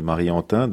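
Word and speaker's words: Mary Antin: C'est Marie Antin.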